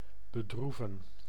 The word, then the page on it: attrister: [at.ʁis.te]; France